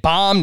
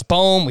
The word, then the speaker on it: Boehm: I mean, we had Baum, now it's Boehm. We had Voth, now it's Voth. What are we doing here, Scott? – We're neglecting the long O.